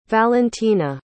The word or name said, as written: valentyna